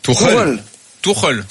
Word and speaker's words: Tuchel: Tu me confirmes comment on prononce? – Tuchel. – Comme une tourelle, ça.